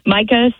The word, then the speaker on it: Mika: People still call me Mika every day. It's always fun when I'm on like the 15th home tour and they still don't know my name's Micah. But Micah spelled like Mika, M-I-K-A, Rotunda.